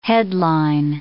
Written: headline

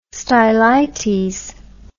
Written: stylites

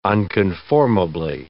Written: unconformably

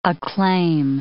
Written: acclaim

